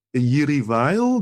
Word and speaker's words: Jiří Weil: Mendelssohn is on the Roof by Jiří Weil, do I pronounce that name correctly? – That's fine. Yeah.